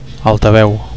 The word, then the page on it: altaveu: Catalan